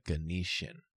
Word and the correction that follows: Ghanaian: Ghanaian. – Ghanaian sounds correct.